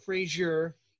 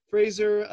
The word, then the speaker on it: Fraser: Make sure I say that right. Not Frazier. It's very different. That's right. Uh, Fraser, uh, Almiron, Mickey Almiron, love that guy.